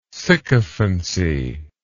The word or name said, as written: sycophancy